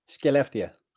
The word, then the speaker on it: Skellefteå: Skellefteå.